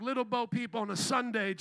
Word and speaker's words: Little Bo Peep: What was the one that Woody was with that had to, Bobo Peep? Little Bo Peep? Bobo Peep. Little Bo Peep.